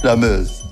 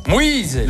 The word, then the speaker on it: Meuse: La Meuse. – Moïse. – La Moïse. – Il prononce mal, il a un petit défaut.